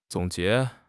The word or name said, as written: zong3 jie2